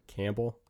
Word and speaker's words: Campbell: Campbell.